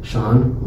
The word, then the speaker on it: Shaun: So I was like, let's create a lawn business, you know, and you know, my name rhymes with lawn, Shaun with lawn, and so I was like, you know what, boom, Shaun Care, you know, what a great idea.